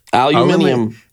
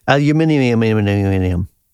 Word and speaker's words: aluminium: Aluminium. – Aluminium. Yeah. – On that, you are correct. Your pronunciation of aluminium is probably more accurate than ours.